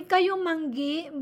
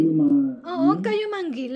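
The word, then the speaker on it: kayumanggi: We say kayumangi, but locals don't say it that much. Just brown is okay. Kayumangi. Let me see. Kayumangi.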